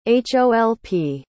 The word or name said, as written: holp